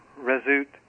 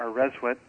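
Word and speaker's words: rswt: So the word for dreaming is normally pronounced rezut or rezwit, and it translates as awakening or to come awake, and so the symbol was the open eye.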